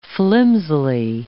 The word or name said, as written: flimsily